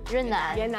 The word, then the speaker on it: Yunnan: And let's try that again, because I was saying it wrong. It's Yunnan? – Yunnan. – Yes, okay. – Yunnan. You got it.